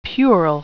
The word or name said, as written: puerile